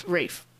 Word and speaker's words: Ralph: And we chose the 1992 one because Ralph. – Rafe. Gosh. Guys, it's spelled Ralph.